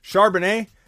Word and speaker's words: Charbonnet: Charbonnel? Charbonnet? I think it's Charbonnet is how you pronounce it.